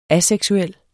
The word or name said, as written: aseksuel